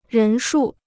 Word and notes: 人数: rén shù

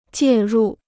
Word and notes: 介入: jiè rù

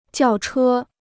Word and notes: 轿车: jiào chē

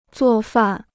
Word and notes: 做饭: zuò fàn

